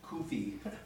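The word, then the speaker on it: CUFI: By the way, the primary vehicle for Christian Zionists in the United States is KUFI, that's how it's pronounced, the Christians United for Christians United as Friends of Israel.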